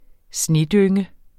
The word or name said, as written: snedynge